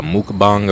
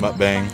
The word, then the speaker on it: mukbang: We're all kind of eating, so this is going to be kind of like a mukbang, or what? How do you pronounce it? – Mukbang.